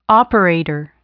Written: operator

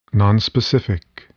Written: nonspecific

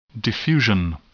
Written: diffusion

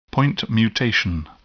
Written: point mutation

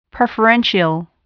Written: preferential

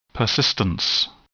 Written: persistence

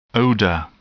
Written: odor

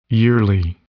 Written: yearly

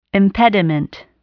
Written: impediment

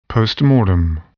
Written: postmortem

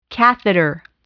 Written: catheter

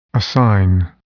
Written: assign

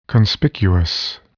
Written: conspicuous

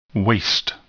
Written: waste

